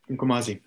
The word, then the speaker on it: Mkomazi: And so I saw that you had written a book on fortress conservation based on your time in, and please correct my potential mispronunciations here, the Mkomazi Reserve in Tanzania. – Yeah, Mkomazi, that's right.